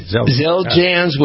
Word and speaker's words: Xeljanz: I'm going to challenge you to pronounce, this is a drug, it's X-E-L-J-A-N-Z, okay.